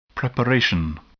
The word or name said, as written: preparation